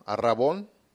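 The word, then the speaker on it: arrabón (ἀρραβών): Bueno, arras viene de la palabra griega arrabón, con B de bueno, arrabón, acento en la O.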